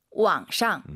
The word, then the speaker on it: 网上: 网上. – 우리가 보통 이제 한국어로 인터넷상에서라고 할때 인터넷상 할때 상이 샹이라고 보시면 될것 같습니다. 왕샹 그 선생님 삼성이 지금 무려 세 개예요.